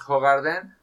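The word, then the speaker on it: Hoegaarden: Muy buena cerveza, les recordamos Hogarden, es este belga.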